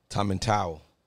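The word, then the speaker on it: Tomintoul: Is it pronounced Tom and Tool? – Tom and Towel.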